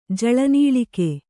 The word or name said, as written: ಜಲನೀಲಿಕೆ